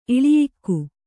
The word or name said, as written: ಇಳಿಯಿಕ್ಕು